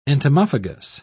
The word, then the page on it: entomophagous: British English